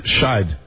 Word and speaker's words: Scheidt: Justin Scheidt is suing the dancers at Showgirl 3. – Are you sure about that pronunciation? – S-C-H-E-I-D-T, German name. Scheidt. – Yes, with a D.